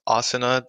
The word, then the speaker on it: Asuna: And yeah, I mean, I don't really care how it's pronounced, but it is pronounced Asuna the Japanese way because I got it from, you know, an anime.